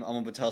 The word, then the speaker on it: Aaman Patel: Double A-Man, if that's a quick way to remember, A-A-M-A-N-P-A-T-E-L, and then Sports News. So Double A-Man Patel Sports News. That's the best way to remember it.